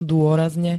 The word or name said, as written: dôrazne